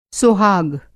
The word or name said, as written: सोहाग